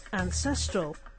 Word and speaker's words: ancestral: As an adjective, the word becomes ancestral, with the stress on the second syllable. Ancestral.